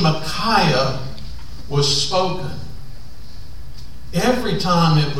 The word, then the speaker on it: Micaiah: The Hebrew name there is not the shortened version of Micah, but the longer version of his name that I would pronounce Micaiah.